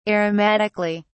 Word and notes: aromatically: British English

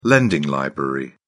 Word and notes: lending library: British English

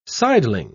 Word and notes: sideling: British English

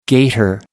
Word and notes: gator: American English

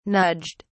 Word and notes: nudged: British English

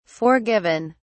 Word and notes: forgiven: British English

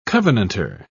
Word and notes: covenanter: British English

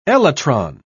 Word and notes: elytron: British English

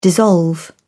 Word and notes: dissolve: British English

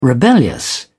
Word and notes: rebellious: British English

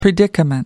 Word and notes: predicament: American English

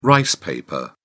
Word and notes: rice paper: British English

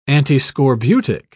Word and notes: antiscorbutic: British English

